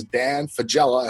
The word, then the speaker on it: Dan Fagella: His name is Dan Fagella.